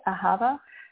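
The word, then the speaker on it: ahava (אהבה): If you know this in Hebrew, so it can be spelled a little bit different in Hebrew because it's H-A-A-B, but when we spell it phonetically and say it in English, if you will, I mean, I guess it's English, but how we would pronounce it, we change, it's H-A-A-V-A, ahava.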